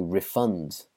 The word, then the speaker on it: refund: Next, refund or to refund. So the verb is to refund someone. So again, emphasis on the second half of the word, refund. You might offer to refund someone if their product was broken, but you would offer a refund.